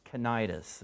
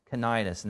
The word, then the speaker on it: Cnidus: And this is pronounced Cnidus. It doesn't look like that, but Cnidus.